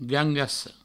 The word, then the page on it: gangassa: gangasso